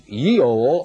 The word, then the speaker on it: Geol: The Nordic word for the winter solstice was Giol, G-E-O-L, the G being pronounced like our Y. thus Yeol or Yule.